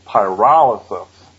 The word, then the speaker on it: pyrolysis: Okay, first, just to be really retentive, the word is pronounced pyrolysis.